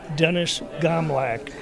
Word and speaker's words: Dennis Gomlak: Dennis Gomlak. G-O-M-L-A-K.